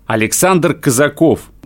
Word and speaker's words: Александр Казаков: И Дальний Восток на этом мероприятии представлял всего лишь один человек – фехтовальщик, боец Александр Казаков, представитель Хабаровского клуба исторической реконструкции «Рось».